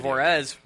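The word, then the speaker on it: Juárez: Call of Juarez, one of Ryan's favorite games ever.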